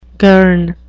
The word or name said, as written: girn